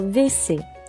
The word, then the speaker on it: WC: 但是呢，在法语中的确存在于这个词，存在着这个词叫 W C 哈，嗯，W C，但是它实际上的发音呢，应该叫做 V C。 VC，OK，好，那么就把 W 两个 V 拆成了一个 V，但是写下来还是 W 这种写法。那我说我要去厕所，叫做 Je vais，我去啊，Je vais au VC，Je vais au VC。